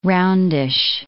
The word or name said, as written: roundish